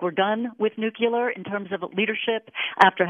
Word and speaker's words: nuclear: and he used nuclear, which seems to be a Texas thing, and it seems to have slipped back in. And quite frankly, I grew up with a father in the electric industry, and he always lectured me whenever somebody said nuclear, so I've always heard nuclear. – Nuclear, which is the mispronunciation, as you said, is actually a very common mispronunciation, and there's actually a linguistic reason for that that we won't go into here.